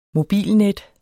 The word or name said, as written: mobilnet